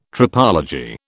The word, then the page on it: tropology: British English